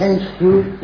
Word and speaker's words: Shen Xiu: Please correct my pronunciation of these names. I'm not familiar with these names. Am I saying it correctly? – Yes. – Shen Xiu? – Yes.